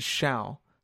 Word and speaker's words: Hsiao: no such name, but there is uh, HSIAO, which is shall. So it could be R shall or okay. shall.